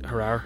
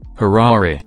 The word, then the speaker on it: Harare: You've seen the massive new parliament building they're building in Harare? – Actually, I believe it's pronounced Harare.